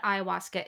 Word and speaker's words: ayahuasca: So, um, to start with what ayahuasca is.